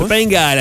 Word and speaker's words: Topanga: Topanga. C'est comme ça que tu le prononces? – Ouais. Topanga, la pièce Fogo Allen.